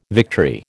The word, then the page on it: victory: American English